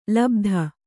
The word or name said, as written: ಲುಬ್ಧ